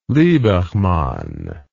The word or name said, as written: liebermann